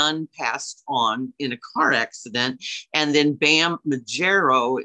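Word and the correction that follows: Margera: is that how you say his name? – Majera.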